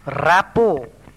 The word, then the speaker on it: rapport: Rapport, R-A-P-P-O-R-T, the T is silent.